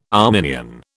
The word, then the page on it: Arminian: British English